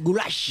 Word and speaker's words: goulash: Es que ni siquiera es una carne, es el goulash es pronunciado así, goulash.